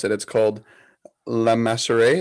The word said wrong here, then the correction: Le Maschere: Is that what it is? – Le Maschere.